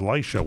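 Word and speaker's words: Lycia: L-Y-C-I-A, I think it's pronounced. I think it's pronounced Laisha when I looked it up.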